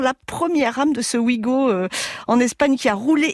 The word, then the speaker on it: Ouigo: Ici aussi, ça se prononce Ouigo.